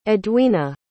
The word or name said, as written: edwena